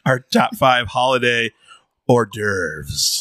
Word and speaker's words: hors d'oeuvres: Juarez Duevres season. I love saying it that way. – Can you say it correctly for the people at home? – Commonly known as hors d'oeuvres. – There we go. – Muse Bouche. – Should be spelled O-R-D-E-R-V-E-S.